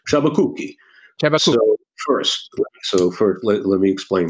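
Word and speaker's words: Sciabacucchi: Chabakuki is at the top of the list, and I explain to lawyers, you just take the name, you break it into two, all right? Chabakuki.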